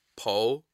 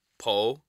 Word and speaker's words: Poalypse: Pronunciation of that is Poalypse. Poalypse.